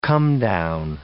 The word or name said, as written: comedown